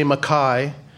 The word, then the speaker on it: Mackay: I never feel any angst towards anybody that doesn't say Mackay, but it's spelled M-A-C, capital K-A-Y, and it's pronounced the Scottish way because that's what my father did, so that's what I do.